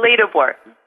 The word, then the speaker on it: Ledeboer: Ledeboer.